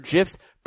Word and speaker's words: GIF: The guy who created GIFs or GIFs pronounced it with a J.